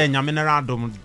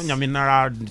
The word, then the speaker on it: Nyaminadum: No, I like the Nyaminadum. You know. – Nyaminadum. – There's no R in there.